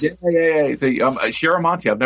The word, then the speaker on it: Chiaramonte: And I'm going to pronounce this incorrectly, but the inker was Charlamenti. You know what I'm talking about, Frank? – Chiaramonte. – Chiaramonte, thank you very much.